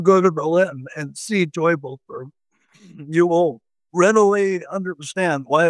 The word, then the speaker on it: Teufelsberg: And you mentioned, now I'm not sure on the pronunciation, is it Teufelsberg, the Sigint site? – Teufelsberg. Devil's Mountain.